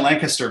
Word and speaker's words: Lancaster: They were, it's funny you mentioned Lancaster, because you said it right.